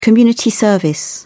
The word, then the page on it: community service: British English